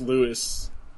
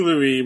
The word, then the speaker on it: Louis: The S is silent. – King Louis was spelled with an S, right? – I think so.